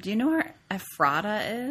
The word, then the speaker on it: Ephrata: There's a little behind-the-scenes joke going on where I mispronounced the name of the town initially to Ephrata. But apparently, if you're from Pennsylvania, you know that it's supposed to be Ephrata.